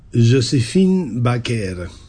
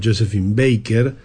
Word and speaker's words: Joséphine Baker: Esto es Tormenta de Cerebros y estábamos escuchando a la francesa Joséphine Baker. Así pronuncian el nombre que normalmente nosotros pronunciamos a la inglesa, Joséphine Baker, los franceses.